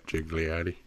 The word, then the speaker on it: Gigliotti: Clerks 3 comes out, but the original Clerks is on this list, starring Brian O'Halloran, Jeff Anderson, Marilyn Gigliotti. Is that how you say it? – Yeah, Gigliotti.